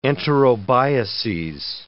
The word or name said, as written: enterobiases